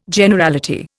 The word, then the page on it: Generality: Indian